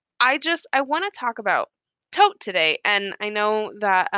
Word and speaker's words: Thoth: So I'm going to be saying tote. I like that more than thoth or toth or thoth, but obviously you're going to say it.